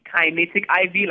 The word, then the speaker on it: KinectIV: So, so it, it, it's actually kinetic IV, like kinetic energy, like oh.